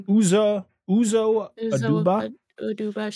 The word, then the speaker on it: Uzo Aduba: Uzo Aduba.